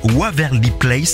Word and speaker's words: Waverly Place: C'est Waverly Place. Ça va, je l'ai bien prononcé. – C'est parfait.